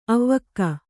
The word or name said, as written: ಆವಕ್